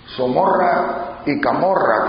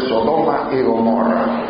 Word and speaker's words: Sodoma y Gomorra: Todos los demás somos Somorra y Camorra, como decía ella, porque nunca en su vida logró pronunciar bien eso de Sodoma y Gomorra.